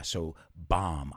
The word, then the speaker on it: bomb: But the B at the end is silent. La B es muda. So bomb.